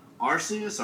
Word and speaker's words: Arceus: Arceus, or however you pronounce it? – Right.